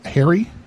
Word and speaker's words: Harry: The first name is Harry. H-A-R-R-Y. – Last name? – It's spelled S-A-C-H-S.